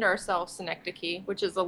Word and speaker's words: synecdoche: Yeah, it is synecdoche. Some people don't pronounce it right, and it actually works out okay, because it opens up a conversation to explain what it is and what we're about.